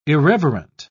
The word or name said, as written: irreverent